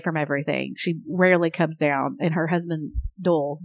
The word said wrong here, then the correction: Doyle: Doyle. Just, what is Dole, his name? Dole. Can you spell that? – D-O-Y-L-E. Doyle. Everyone, nope, everyone call him Doyle.